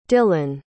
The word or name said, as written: dylon